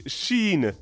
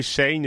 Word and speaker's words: Shein: Est-ce qu'il faut l'appeler Shein? Est-ce qu'il faut l'appeler Shein? Elle s'écrit S-H-E-I-N. Chacun aura sa prononciation.